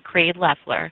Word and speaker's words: Creed Leffler: You can follow me on Twitter by typing in the @ and my name, Creed Leffler. That's spelled CREEDLEFFLER.